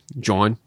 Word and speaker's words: jawn: Well, that's my brother's name pronounced in Pittsburgh. John. – Tony, will you look up John? – Yeah. – J-A-W-N.